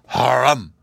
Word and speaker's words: Harum: "Harum!" he roars. "Harum!" with the accent on the rum.